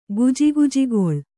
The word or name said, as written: ಗುಜುಗುಜುಗೊಳ್